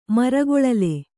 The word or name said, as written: ಮರಗೊಳಲೆ